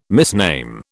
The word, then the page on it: misname: British English